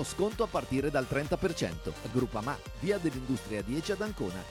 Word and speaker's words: Groupama: S-P-O-T. Spot. – Groupama. Ah no, si pronuncia Groupama.